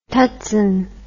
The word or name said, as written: tutsan